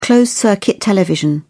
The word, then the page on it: closed-circuit television: British English